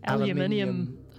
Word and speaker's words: aluminium: Aluminium. Aluminium. – We say aluminium. – Because that's the correct way to say it.